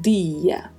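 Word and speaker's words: día: E lembrando que em espanhol, sempre que tivermos uma palavra com essa construção di, di, não se pronuncia di, é sempre di. Então pronunciamos dia.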